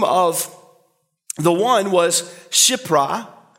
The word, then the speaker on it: Shiprah: The emphasis is on the end, on the awe. All right, say it with me. Shiprah, Puah. Shiprah and Puah. Say it together. Shiprah and Puah.